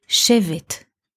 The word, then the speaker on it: Shevet: Those letters also refer to a noun, which is written in the same letters but pronounced a bit differently, Shevet. Shevet means in Hebrew a rod, a cane, a stick, Shevet.